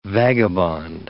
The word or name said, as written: vagabond